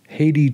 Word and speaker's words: Hathi: Haiti Trust. It looks like Hathi Trust, but they say it's pronounced Haiti, much like the country.